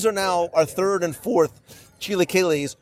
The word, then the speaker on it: chilaquiles: Chilaquiles.